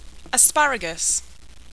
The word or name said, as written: asparagus